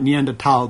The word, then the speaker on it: Neanderthal: Well, originally it was, yeah, you're right, Neanderthal, because the H isn't pronounced in German. Anyway. – Right, but in America, we Americanized it to Neanderthal, and that's what everyone has in their head.